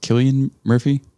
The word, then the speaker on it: Cillian Murphy: Are you familiar with, I believe it's pronounced Killian Murphy? – No.